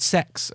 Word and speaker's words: CEX: I've picked it up in CEX, which I learned was called sex, according to their YouTube adverts. – It is, yeah. – It's spelled CEX, though.